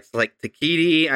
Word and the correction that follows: Taika Waititi: I can never say his name. Anybody? – Taika Waititi. – There you go.